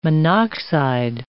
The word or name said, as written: monoxide